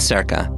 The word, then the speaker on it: Cerca: In Circa, that's spelled C-E-R-C-A,